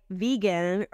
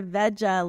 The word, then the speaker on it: Vega: Because I thought Vega, like vegan, or Veja, like yes. Vegetarian. So and the only way I know it's pronounced Vega is I called the company after hours to listen to it on their voicemail.